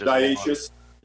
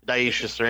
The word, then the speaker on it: dioecious: And then another interesting part is that hops are actually dioecious, if that's how you pronounce it, which means dioecious. – Dioecious, right? There you go.